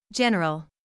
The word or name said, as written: general